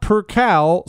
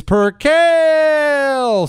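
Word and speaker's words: percale: It's obvious, but it's not per cal soft A. It's per cal long A.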